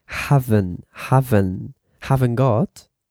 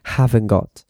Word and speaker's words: haven't got: You pronounce haven't got. You don't have to pronounce the T, okay? The letter T, you don't have to pronounce it. So you say haven't got.